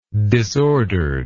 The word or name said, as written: disorderedness